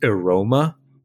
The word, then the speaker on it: AROMMA: So this is I think the acronym is supposed to be pronounced AROMA, but it's AROMMA. So I think that's AROMA, if you'll indulge me.